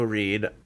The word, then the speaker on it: Reid: You can find me there at My username there is Joe Reed. Reed is spelled R-E-I-D, and that's the same as my Twitter handle, which is at Joe Reed. R-E-I-D is how you spell that.